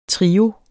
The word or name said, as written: trio